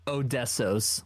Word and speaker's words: Odessos: Yeah, you said Odessos is one, or am I saying that right? I'm sorry, Odessos? – Odessos, like O-D-E-S-S-O-S.